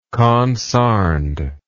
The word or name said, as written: consarned